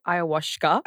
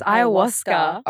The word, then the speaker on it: ayahuasca: I love that you say ayahuasca. – How do you say it? – It's ayahuasca. – Ayahuasca? – Yeah, you've been saying it wrong this whole time.